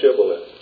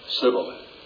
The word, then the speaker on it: Shibboleth: Say Shibboleth. – civilly.